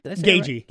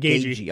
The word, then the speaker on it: Gaethje: Gaethje. – Right? – Gaethje.